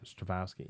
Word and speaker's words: Strahovski: Is Yvonne Strahovski listed? – No. – Yeah, she's in it too. – Okay. – I think I pronounced her name or last name right. – Strahovski, yeah.